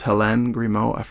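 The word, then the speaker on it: Hélène Grimaud: And I think it was Helene Grimaud. I think that's how you pronounce it. – Yes, exactly.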